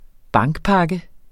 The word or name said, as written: bankpakke